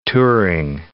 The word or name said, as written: Turing